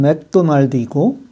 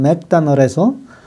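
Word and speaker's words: McDonald's: 오늘은 한국식으로는 맥도날드이고 미국식 발음으로는 맥다널에서 먹거리를 싸게 이용하는 방법과 배달앱에 대해 말씀드리겠습니다.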